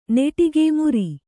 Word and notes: ಮೆರೆ: Kannada

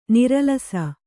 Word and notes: ನರಲಿಸು: naralisu

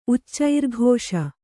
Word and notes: ಉಚ್ಚೈರ್ಘೋಷ: Kannada

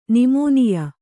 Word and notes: ನಿಮೋನಿಯ: nimōniya

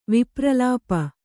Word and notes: ವಿಪ್ರಲಾಪ: vipralāpa

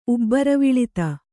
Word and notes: ಉಬ್ಬರವಿಳಿತ: ubbaraviḷita

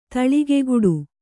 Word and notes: ತೋಳ್ಕೈಗೊಡು: tōḷkaigoḍu

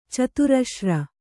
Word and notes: ಚತುರಶ್ರ: caturaśra; Kannada

